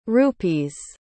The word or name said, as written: rupees